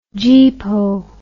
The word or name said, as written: geepo